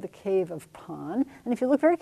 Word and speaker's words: Pan: Pan, if you want to think of how to spell it, P-A-N, pronounce it Pan.